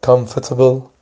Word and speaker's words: Comfortable: Comfortable، آرش میتونه تلفظ بشه یا نشه که معمولا نمیشه.